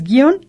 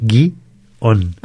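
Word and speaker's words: guion: Entonces, guión es una sola sílaba y no va acentuado. – Así es. La confusión es que mucha gente la divide en sílabas y tiende a decir guión.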